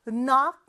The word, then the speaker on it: knock: N 앞에 묵음이 하나 있었어요. 뭔지 기억나요? 바로 K였죠. 그래서 KN으로 시작하는데 이렇게 KN으로 시작하는 단어들은 K 발음을 해주지 않는다고 했어요. 자, knock, 어 발음이니까 O가 오겠고 knock, 끝에는 CK로 끝나죠.